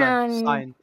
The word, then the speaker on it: Cyan: The color, Cyan. C-Y-A-N. – Oh, yeah. Cyan. And he's a scientist, so Cyan the scientist? – That's terrible.